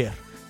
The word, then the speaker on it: Voltaire: o no sé cómo se pronuncia bien en francés. – Voltaire. – Gracias.